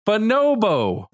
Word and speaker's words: bonobo: The first O is not stressed like that. It's bonobo. – No, my emphasis is on the second syllable.